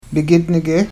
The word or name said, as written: bgidnige